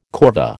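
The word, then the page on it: corvée: American English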